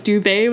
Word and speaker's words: Dubé: Like almost like my last name, like Dubé with like an accent on the E.